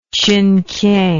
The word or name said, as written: chinkiang